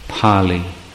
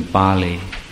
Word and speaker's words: Pali: Now, you notice I pronounce that as Pali rather than Bali.